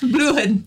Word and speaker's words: blühen: Die Blumen Blüha. – Blühen. – Blühen.